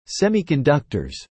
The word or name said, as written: Semiconductors